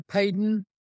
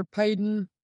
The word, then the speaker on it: Peden: As far as the Covenanters themselves, after Knox's death, you have men that stood out, such as Alexander Payden. That's spelled P-E-D-E-N.